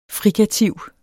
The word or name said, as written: frikativ